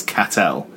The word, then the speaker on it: Castel: This is really, really boring for everyone, but we have a parish in Guernsey spelt Castel, which we pronounce Catel.